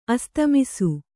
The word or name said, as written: ಅಸ್ತಮಿಸು